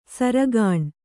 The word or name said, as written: ಸೆರೆಗಣ್ಣು